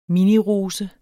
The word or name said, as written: minirose